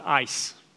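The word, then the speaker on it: eis: And it's E-I-S, pronounced ice.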